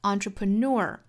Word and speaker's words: entrepreneur: 这个词在美音里还有一种读法，就是 entrepreneur，后面的 ner 可能会读成 nur，练听力的时候听到这个词有两种读法也不觉得奇怪就可以了。